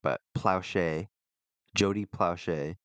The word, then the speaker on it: Plauché: Plowshay.